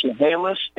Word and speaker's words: Chehalis: And it's pronounced Chehalis and Okanagan, and I forgot what you said the third one was.